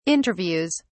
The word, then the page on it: interviews: British English